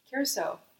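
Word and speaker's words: Curaçao: And earlier tonight, I found out it's not Caraco. I thought it was Blue Caraco. – Curacao.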